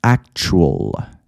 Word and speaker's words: actual: actual، actual نمیگم actual، اون A تلفظ نمیشه.